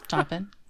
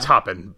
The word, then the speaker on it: Taupin: Taupin? You guys know how to say it? Taupin? It is Taupin? – Taupin. Yeah. – I shouldn't doubt myself. I had it right the first time.